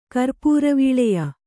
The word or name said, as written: ಕರ್ಪೂರವೀಳೆಯ